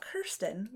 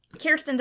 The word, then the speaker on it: Kirsten: And Kirsten Dunst, not Kirsten, which I think I mispronounced on the Beguiled podcast a bunch, mm-hmm. but Kirsten Dunst is such a relatable actress that she really draws you in to this story, and it's so well written.